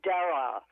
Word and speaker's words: Darragh: Lydia Darragh. I think she's Irish, so it's D-A-R-R-A-G-H, Darragh, I think it is.